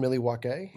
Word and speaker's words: Milwaukee: Maybe not Milwaukee or Baltimore. – It's pronounced Milwaukee. And it means the good land.